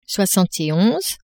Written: soixante et onze

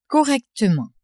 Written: correctement

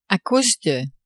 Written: a cause de

